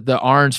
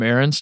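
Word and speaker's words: Arnz: I always called him Arons just because that's how it looked to me. And then when I met Justin for the first time, who plays at Ohio State, he was like, no, you dumbass, it's Arns.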